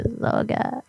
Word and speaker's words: Zagat: Zagat. – That sounds wrong. – It does, but I think that's how it's spelled. – I asked Google how to say it just to make sure it's a Zogat.